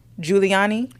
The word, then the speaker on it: Giuliani: Giuliani.